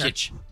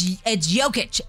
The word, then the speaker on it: Jokic: Jokic. – Ah, son of a bitch. So it's Jokic.